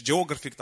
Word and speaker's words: Geographic: Є такий канал National Geographic, чи Geographic, там так правильно наголос.